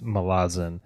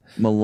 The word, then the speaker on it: Malazan: I heard Steven Erikson in an interview once say it's Malazan. – Malazan.